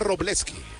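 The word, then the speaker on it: Wrobleski: Este pitcher escribe su apellido con la letra W al inicio y luego la R. Se pronuncia Robleski. La W prácticamente no se pronuncia.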